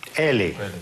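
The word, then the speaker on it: ele: «Эли». «Эли». То есть безударное «о» читается как «у», безударное «э» читается как «и».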